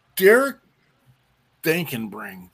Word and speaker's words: Derek Dankenbring: We'll Derek Dankenbring.